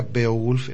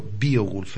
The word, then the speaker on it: Beowulf: Se pronuncia Beowulf en anglosajón, pero Beowulf en inglés, ¿eh?